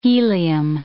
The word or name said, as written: helium